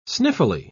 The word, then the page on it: sniffily: British English